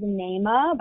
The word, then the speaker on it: Naamah: Okay, second, I'm going to choose Naima by Sarah Blake. That is spelled N-A-A-M-A-H. – Okay.